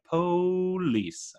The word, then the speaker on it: police: That's how they say it in the show. They're like, police. I don't know why. I think that's a Baltimore thing.